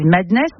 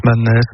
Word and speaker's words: MDNS: Ultra Violent, avec, je crois, on dit Madness, mais ça s'écrit MDNS. – Ouais, MDNS, Madness, Mad.